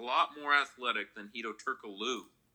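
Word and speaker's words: Türkoğlu: There's no G. G is silent. – There's a G in there, so that's why I say Turkoglu.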